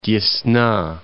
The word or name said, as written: Desna